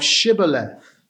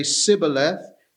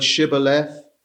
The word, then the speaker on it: Shibboleth: If you can't say the sh and you say Sibboleth, you are an Ephraimite, and so therefore they would slaughter them.